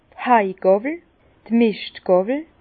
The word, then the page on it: d' (Hai-Mischt) Gàwwel: Bas-Rhin; Herrlisheim